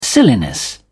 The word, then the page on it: silliness: British English